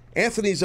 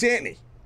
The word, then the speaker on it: Anthony: If you say it like Anthony, it's very... – Regal. – Regal. Yeah, you read my mind. But you know, New York street slang, you know, Paisan feel, it's Antony.